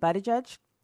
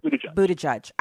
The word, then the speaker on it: Buttigieg: Your issue with what I said about Pete Buttigieg, your issue is with Buttigieg.